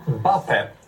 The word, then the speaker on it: Mbappé: Ja, Mbappé, rätt uttal.